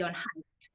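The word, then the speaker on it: Jon Haidt: I can't pronounce John Haidt.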